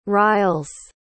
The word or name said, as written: riles